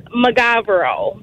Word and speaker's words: Mogavero: So our last name is Magavero. – Magavero. – It's spelled M-O-G-A-V-E-R-O. – What did they say? – A lot of people pronounce it Mogavero, which is fine.